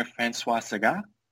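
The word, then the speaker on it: Françoise Sagan: I really admire François Sagan, who is French. Again, yes. – I don't think I'm pronouncing that correctly. – No, you got it right.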